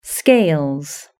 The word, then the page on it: scales: British English